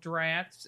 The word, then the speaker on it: draughts: I think in the inflaming of the brain, and therefore much used in confusing and befuddlement drafts. It says draughts, right? Or drafts. – Yep, drafts. Draughts. – Yeah, but I think it's pronounced draft.